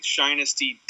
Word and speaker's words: Shinesty: Shinesty.